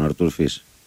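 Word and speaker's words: Fils: Τον Αρτούρ Φις. – Αυτό είναι, χωρίς το S δεν το προφέρουν οι Γάλλοι.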